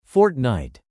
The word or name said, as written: fortnight